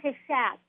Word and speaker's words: Cushatt: So I say Cushatt, like hat.